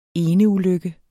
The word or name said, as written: eneulykke